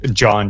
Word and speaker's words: Jean: Oh, it's like John, just pronounced like John.